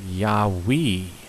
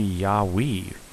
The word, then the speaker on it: Yahweh: The Hebrews pronounced it Yahweh with the emphasis on the we, the stress is given on the we, Yahweh, which I thought was very interesting.